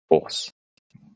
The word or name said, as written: Force